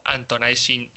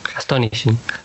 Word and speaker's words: Astonishing: y también tuvo experiencia en la editorial, escribió historias tan famosas de los X-Men como Antonizing X-Men. – Astonishing.